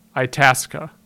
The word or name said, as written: Itasca